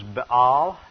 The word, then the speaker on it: Baal: The god was Baal in the Hebrew. Most people pronounce it Baal in English.